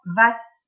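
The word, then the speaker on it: Wace: Donc on va avoir donc, Geoffroy de Monmouth, on va avoir Vas ou Was, hein, c'est écrit avec un W, donc selon où vous êtes, on ne prononce pas de la même façon, euh, mais qui euh, reprend un petit peu cette histoire dans son roman de Brut, donc en langue française.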